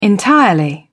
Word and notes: entirely: British English

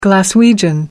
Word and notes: glaswegian: American English

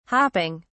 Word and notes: hopping: British English

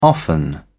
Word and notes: often: British English

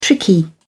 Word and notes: tricky: British English

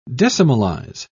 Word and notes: decimalise: British English

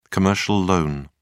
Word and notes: commercial loan: British English

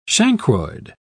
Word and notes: chancroid: British English

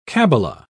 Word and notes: cabbala: British English

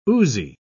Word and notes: oozy: British English

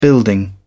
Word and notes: building: British English

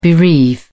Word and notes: bereave: British English

